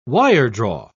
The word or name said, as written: wiredraw